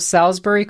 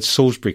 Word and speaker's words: Salisbury: Full marks, by the way, for getting the word Salisbury correctly pronounced. I'm impressed. You should get points. cool. – I was expecting you to say Salisbury.